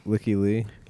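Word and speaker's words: Lykke Li: How do you say it? Leaky Lee or whatever? Leaky Lee? – Yeah. Leaky Lee.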